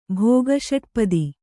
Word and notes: ಭೋಗಷಟ್ಪದಿ: bhōga ṣaṭpadi

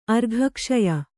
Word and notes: ಅರ್ಘಕ್ಷಯ: kannada

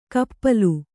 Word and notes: ಕಪ್ಪಲು: kannada

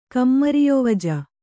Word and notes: ಕಮ್ಮರಿಯೊವಜ: kammariyovaja

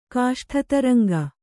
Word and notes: ಕಾಷ್ಠತರಂಗ: kāṣṭha taraŋga

